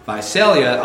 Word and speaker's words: Visalia: It's not Visalia, it's Visalia. – It's not Sal, it's Sale.